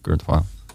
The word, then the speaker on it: Kurt Vile: Eh, dus dan ga ik nu over naar Pretty Pimpin van Kurt File. Zeg je dat wel? Kurt File? Denk het wel. Ik heb dat nooit uitgesproken. Kurt Vile. Vet. – V-I-L-E. Ja.